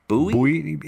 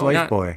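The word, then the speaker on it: Lifebuoy: Again, I always pronounced it Bowie. – It's Life Boy.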